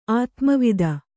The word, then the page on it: ಆತ್ಮವಿದ: ātmavida